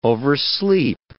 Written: oversleep

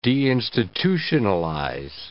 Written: deinstitutionalize

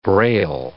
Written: Braille

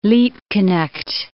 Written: Liebknecht

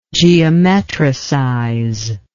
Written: geometricize

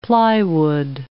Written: plywood

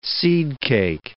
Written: seedcake

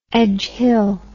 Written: Edgehill

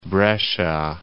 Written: Brescia

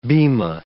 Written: bema

